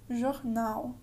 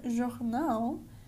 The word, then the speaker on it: jornal: And yeah, so next we can see an example with the word jornal, meaning newspaper.